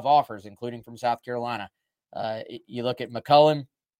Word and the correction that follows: McCulley: he switched over. McCulley, I keep saying his name wrong.